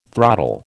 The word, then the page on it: throttle: American English